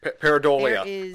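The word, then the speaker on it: pareidolia: And the only thing I wanted to mention before we go, so you talked so much about Paradalia. – Paradolia. I'm the only one that can pronounce it.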